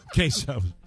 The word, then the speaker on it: queso: Castle? – Queso. – Queso. – That's how you pronounce it.